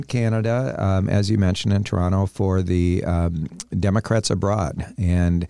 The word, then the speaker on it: Toronto: And you two were just in Toronto, right? – We were. – The second tea is silent.